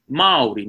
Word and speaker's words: Māori: So for example, the correct way to say Māori is Māori. Now, there's a macron on there, a macron over the first A, which means it's a long A sound. So it's Māori. Okay, it sounds a little bit like the word mouldy, as in a mouldy cheese or a mouldy piece of bread or something.